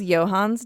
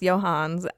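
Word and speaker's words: Johne's: So the last one is Johann's disease, and it's spelled like John's disease, but it is definitely pronounced Johann's.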